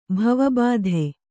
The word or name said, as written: ಭವಬಾಧೆ